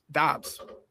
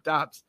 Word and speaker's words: Doubs: And Watson now is gonna obviously clearly be behind Dubs, Doubs. We learned how to say his name, Doubs.